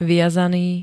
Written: viazaný

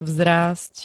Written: vzrásť

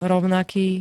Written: rovnaký

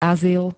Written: azyl

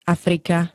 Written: Afrika

